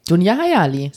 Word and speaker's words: Dunja Hayali: Und da war, oh, ich weiß nicht, wie sie ausgesprochen wird, Dunja Hayali? – Dunja Hayali. – So wird die ausgesprochen, ne? – Absolut.